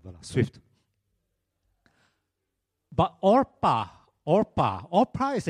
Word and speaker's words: Orpah: You see, what happened there was people mispronounced Orpah as in Oprah Winfrey to Oprah so many times that she just gave up.